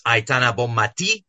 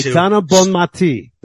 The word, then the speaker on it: Aitana Bonmati: First of all, pronunciation lesson. It's Aitana Bonmati. You have to. – Aitana Bonmati. There we go. – Yeah. You have to stress the I.